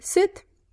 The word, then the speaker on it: suit: In English, the word is suit. Suit. – Right, suit. En este caso, la I es muda.